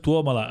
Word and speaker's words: Tuomala: Helsinki és quan Tuomala, que tothom diu Tuomala, i és Tuomala, és esdrúixola també.